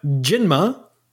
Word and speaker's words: Jinma: No, yeah, but Jinma. – Wait, what? – That's how you say it in Chinese.